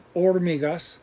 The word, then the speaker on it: hormigas: Remember, H is silent, hormigas.